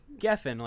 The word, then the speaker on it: Geffen: Okay, David um, Geffen, I think is how you pronounce it. – My oh, apologies. he's like Geffen, like Geffen Records.